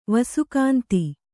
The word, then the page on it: ವಿಸ್ಕಂದ: viskanda